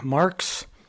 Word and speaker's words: Marx: Mr. Chairman, members of the committee, my name is Glenn Marks. I'm with the Montana Association of Land Trust. Glenn is spelled G-L-E-N-N. Marks is M-A-R-X.